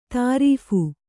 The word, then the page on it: ತರ್ಫಿ: tarphi